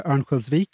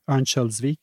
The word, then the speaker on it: Örnsköldsvik: Örnsköldsvik, or Örnsköldsvik, if you're pronouncing it from northern Sweden, this week expressed an interest in hosting next year's Eurovision Song Contest after Loreen's victory in Liverpool last weekend.